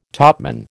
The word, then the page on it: topman: American English